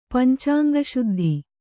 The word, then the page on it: ಪಂಚಾಂಗಶುದ್ಧಿ: kannada